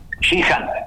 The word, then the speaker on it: Shihan: Shihan. – Shihan, exacto.